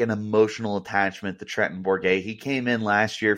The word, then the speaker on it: Bourguet: And then you have Trenton Borgo. Is that how you say his name? Who Bourguet. – Bourguet.